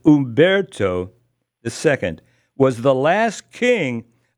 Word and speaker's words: Umberto: Well, they pronounce it. It's H-U-M, but you don't. If you'd hear it, there's a Umberto.